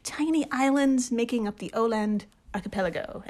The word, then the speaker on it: Åland: I think it's Åland. I looked it up. It's an A with a little circle above it, and it's pronounced O.